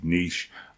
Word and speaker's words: niche: And, you know, by the way, I am British, and so I pronounce it niche. I know that my North American cousins may pronounce it slightly different, but I'm just going to run with niche for now.